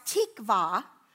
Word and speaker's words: tikvah: In Hebrews, hope is pronounced tikvah.